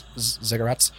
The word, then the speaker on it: ziggurats: So you guys are kind of standing in the southwest corner of these two fairly large cubic pyramids known as ziggurats. I mumbled that word, so I don't have to say it right. – You're saying it right.